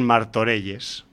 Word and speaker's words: Martorelles: Pero tenemos aquí una historia, una peli sobre el narco mexicano y sus secuaces, pero rodada en Martorelles. No sé si lo pronuncio bien, ¿eh? – Sí, está bien.